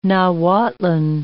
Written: Nahuatlan

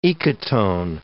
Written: ecotone

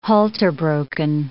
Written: halter-broken

